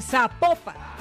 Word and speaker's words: Zapopan: Oiga, nos escriben, no, nos escriben los oyentes y que no, no se pronuncia Zapopan, sino que se pronuncia Zapopan.